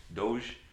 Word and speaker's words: Doge: Is that how you say it? Dog coin? – Dog coin. – Or is it Dog. Doge. It's a meme.